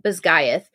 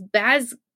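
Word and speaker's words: Basgiath: But the author was like, I pronounce it Bisgaeth. And the reporter was like, oh, I thought it was Bazgiath.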